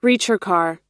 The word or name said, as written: ricercar